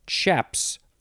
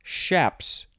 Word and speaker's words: chaps: Next is chaps or shaps. You could pronounce the beginning with a CH or an SH.